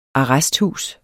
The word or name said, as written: ARRESTHUSET